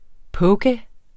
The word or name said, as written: poke